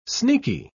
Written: sneaky

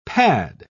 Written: pad

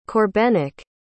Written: Corbenic

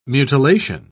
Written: mutilation

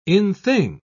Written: in-thing

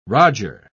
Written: roger

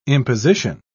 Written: imposition

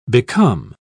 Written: become

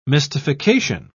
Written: mystification

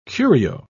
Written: curio

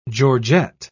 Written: georgette

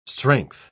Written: strength

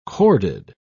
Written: corded

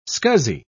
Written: scuzzy

